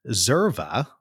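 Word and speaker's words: Xerva: and you created a company called Zerva, but spelled with an X, which is sort of a third-party provider for franchisees, where you guys obviously do the done-for-you data warehousing.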